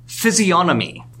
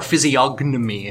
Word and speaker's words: physiognomy: Let's go to theory 9, physiognomy, or physiognomy if you want to pronounce that G, which no.